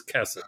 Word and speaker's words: chesed: Now, most Hebrew words, you don't need this, but it's worth knowing, most Hebrew words are pronounced with the emphasis on the final syllable. But in fact, the word chesed is one of the special words where you pronounce it with the accent on the first syllable. So it is chesed.